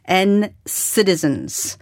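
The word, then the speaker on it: NCTzen: Um, NCT has and, and citizens, uh, pronounced N citizens, a Super Junior fans ELF, mm.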